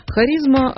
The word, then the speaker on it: Харизма: Харизма, а в русском языке оно произносится именно так, очень древнее слово.